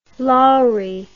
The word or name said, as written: Lowrie